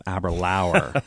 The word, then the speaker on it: Aberlour: When it's happy hour, think of Aberlour. Easy right. – way to pronounce.